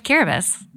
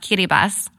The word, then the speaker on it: Kiribati: And I went to the Republic of Kiribati, actually pronounced Kiribati.